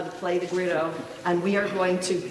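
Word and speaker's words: güiro: and they're called guiros. And I've been wanting to try to learn, am I saying that right, Jose? Guiro? Guiro.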